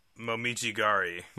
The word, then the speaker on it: Momijigari: Yeah, Momijigari.